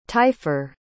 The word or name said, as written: taillefer